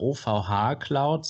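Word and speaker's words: OVHcloud: OVHC oder OVH Cloud, so wird es ausgesprochen, will die Server nämlich zukünftig hosten.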